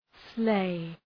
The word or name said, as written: sleigh